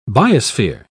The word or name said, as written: biosphere